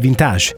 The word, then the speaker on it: vintage: Questo è il nostro mondo ed è vintage. Sì, con la pronuncia alla francese, come piace a me.